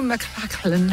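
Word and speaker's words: McClanahan: Rue McClellan, I'm saying it like that because I think that's how you say it.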